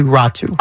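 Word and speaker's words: Räty: I know, because it's spelled R-A-T-Y. – Yeah, okay. – Atu Ratu.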